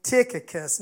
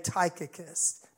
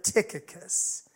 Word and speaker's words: Tychicus: And one of those people are this individual I read about tonight named Tychicus. Now, that is the right pronunciation of his name, although I'm always tempted to say Tychicus, but Tychicus.